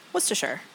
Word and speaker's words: Worcestershire: And then like 6 shakes of, how do you say Worcestershire? – Worcestershire, I think. – Okay. – It is Worcestershire.